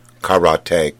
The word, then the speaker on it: karate: Properly pronounced, of course, it's karate. Kara means empty and te means hand.